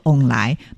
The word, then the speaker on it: 旺来: 对，除此之外呢，其实啊，还有就是我们在台语当中的凤梨啊，其他的读音叫做翁来，那翁来呢就是有好运旺旺来的意思，